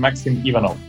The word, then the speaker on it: Maksim Ivanov: Almost. I'm Maksim Ivanov.